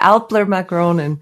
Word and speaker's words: Älplermagronen: Yeah, Älplermagronen.